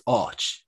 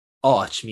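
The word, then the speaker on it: áit: And then the word A with a father over it, I-T, is Och. So it changes the at to an aw. – Yeah. – And Och means place.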